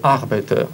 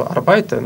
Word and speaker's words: arbeite: Единственное, что в работу arbeite ударение на первый слог. У нас, конечно, как-то, ну, вообще для русского языка более свойственно в длинных словах ударение на первый слог не ставить, поэтому нам привычнее, конечно, сразу арбайты, но так не очень хорошо говорить. Ich arbeite.